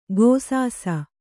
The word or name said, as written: ಘೈಸಾಸ